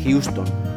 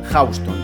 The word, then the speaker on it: Houston: En cualquier contexto sería Houston, pero cuando esa palabra está asociada a la calle neoyorquina es Houston. Así que Houston Street no es Houston Street, sino Houston Street.